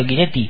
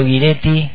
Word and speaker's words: Guiretti: del disco de Mauro está bien pronunciado tu apellido Guiretti? sí, ¿sí? muy bien. – Mauro Guiretti con su disco Esclavo de la Libertad, homenaje a Facundo Cabral, imperdible esta fecha.